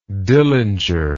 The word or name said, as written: Dillinger